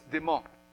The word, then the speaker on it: Démas: Démas, ok, pas de S. On prononce pas le S. Démas.